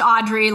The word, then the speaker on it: Audriix: So it's a silent X, double I. So it's, it's pronounced Audrey, like Audrey Hepburn, but A U D R I I X.